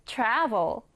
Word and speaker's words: travel: 읽을 때는요, 앞에 강세를 강하게 주세요. 읽어보면 travel 이렇게 발음이 되겠죠? 자, 우리 쓰는 방법도 알아야 하니까 오른쪽에 쌤이랑 같이 한번 적어보도록 하겠습니다. travel, T-R-A-V-E-L 읽을 때는 travel이 되겠습니다.